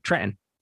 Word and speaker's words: Trenton: And in New Jersey, how would you pronounce the name of that city? – Trenton. – That's how we say it at home. Trenton. – Trenton. Yeah, we don't say the T, right?